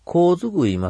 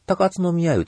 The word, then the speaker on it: 高津宮: で、僕はね、もう、高津宮います、言うてますけど、これ、高津宮って読めなくてね、高津の宮言うて読んでました。高津の宮っていうのは実は古代の、呼び名で、今は高津宮、高津さん言うんですね。